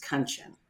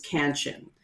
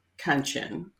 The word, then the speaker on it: Kanchan: the way my family pronounces my name, which is Kanchan. Mm. And I have done it with a sense of pride, whereas before, the anglicized version is Kanchan. I honestly respond to both. The difference is me. I now say my name is pronounced Kanchan, rhymes with luncheon, in case you're wanting to know.